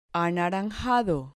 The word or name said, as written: anaranjado